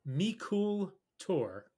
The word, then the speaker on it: Meikle Toir: It's pronounced Mikul Tor. – Two words. M-E-I-K-L-E. T-O-I-R.